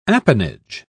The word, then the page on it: appanage: British English